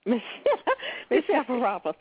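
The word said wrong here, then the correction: Les Misérables: Say Les Miserables. – Les Miserables. – Okay, that's close enough.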